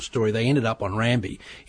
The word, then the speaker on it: Rabi: So on one of the islands we sailed to, a place called Rambi, pronounced Rambi and spelt R-A-B-I in the Fijian fashion, we've got a population of Micronesians.